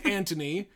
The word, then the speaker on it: Anthony: Thought you said it was Anthony. – It is Anthony, but... us Americans love to pronounce the TH because we can.